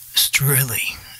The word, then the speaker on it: Strehli: Now, Angela Strehli, S-T-R-E-H-L-I, Strehli.